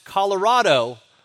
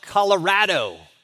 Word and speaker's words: Colorado: I caught that the, the state is pronounced Colorado, not Colorado, like I hear some people say.